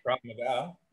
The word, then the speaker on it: Krav Maga: Krav Maga, the martial art.